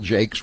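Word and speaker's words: Jacques: Steve Lynch, Cheryl Jakes, rhymes with fakes,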